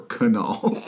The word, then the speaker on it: Knull: Creature's name is, I don't know if you pronounce it, K-N-U-L-L. So either Null with a K like knife or Kunal, which is how I'm going to pronounce it.